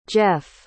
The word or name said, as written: geoff